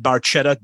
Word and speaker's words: Barchetta: To the Red Barquetta, Red Barchetta, Getty admits that he pronounced it wrong. It is a hard C sound.